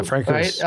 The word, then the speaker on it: Francouz: And I don't want to say the guy's name wrong, but the goalie here, Pavel Francouz. – Yeah, Francouz. Yeah, Francouz.